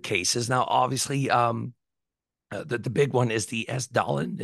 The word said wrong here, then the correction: Hessdalen: Hasdalen, yeah.